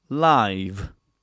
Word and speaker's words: live: And it's, again, confusing because some people don't know how to pronounce the final sound as a voiced sound. So they would just pronounce these two words, live and life, in the same way.